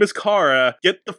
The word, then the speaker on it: Kara: They call her Kara throughout the whole movie. Her name is fucking Kara. Drove me nuts the entire movie. Her name is Kara. It's a short A, not a long A.